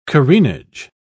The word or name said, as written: careenage